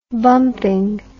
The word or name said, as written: bumping